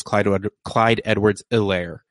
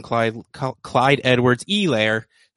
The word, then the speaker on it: Edwards-Helaire: The first show they made it a very big point to remind everyone every 13 minutes or so that you do not pronounce the H and that it is Clyde Edwards-Helaire. But tonight, they are straight up going Clyde Edwards-Helaire.